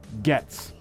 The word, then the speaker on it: Getz: Crew says it's pronounced Getz. – Ah. – So we went Gates and we went Goats. We were both wrong. We should have met in the middle. It's the old silent O.